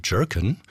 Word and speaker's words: Jurken: Jerkin. Der Musiker aus Illinois schreibt seinen Nachnamen so, wie laut landläufiger Meinung in Berlin wohl der Plural der Gurke ausgesprochen wird, also J-U-R-K-E-N.